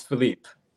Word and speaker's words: Philippe: It's Philippe.